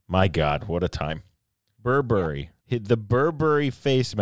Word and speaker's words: Burberry: Burberry, it's Burberry. – Burberry. – So you kind of rush past it.